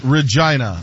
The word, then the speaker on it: Regina: It's Regina. That's the way it's pronounced.